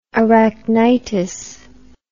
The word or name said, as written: arachnitis